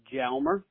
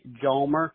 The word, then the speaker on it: Hjalmer: And like you said, my name is Ben Hjalmer, and some people say Hjalmer. Siri likes to say Hjalmer because in Sweden it's pronounced with a Y.